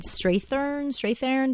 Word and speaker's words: Strathairn: Like David, we didn't even talk about David Strathairn.